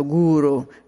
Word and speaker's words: guru: La primera cosa que me gustaría decirte Devi es que yo sé que en español se dice guru, eh, pero guru es una palabra sánscrita, es prácticamente casi tiene el valor de un mantra, por eso que yo prefiero usarla en sánscrito, guru, la acento en la primera U, es muy importante porque guru significa lo que te lleva de la oscuridad a la luz guru.